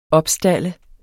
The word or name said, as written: opstalde